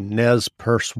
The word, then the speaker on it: Nez Perce: And if someone listening isn't familiar with the Nez Perce War, and did I pronounce that correctly? – Yes, you did.